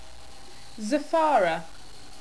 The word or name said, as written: zafara